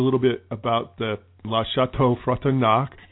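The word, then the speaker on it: Château Frontenac: Fairmont Le Chateau Frontenac.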